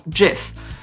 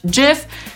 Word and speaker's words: GIF: Rzeczywiście twórca tego formatu tłumaczy, że należy to wymawiać jako GIF.